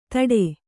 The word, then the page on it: ಧಡೆ: Kannada